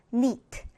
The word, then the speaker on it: knit: 앞에 K 발음이 나지 않고 무음 처리되어서 knit.